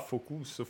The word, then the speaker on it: Focus: senti come lo dico con la pronuncia francese, essendo loro francesi. Ieri mi hanno cazziato, allora oh, Focus, Focus.